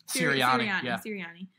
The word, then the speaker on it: Sirianni: From Wyatt, Sunday at Pittsburgh, which will be a miracle in itself, could we possibly see a Nick Sirianni, did I say that right? – Sirianni, called offense in week 17 just as an experiment, since we'll likely already be mathematically in the playoffs.